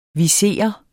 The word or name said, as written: viser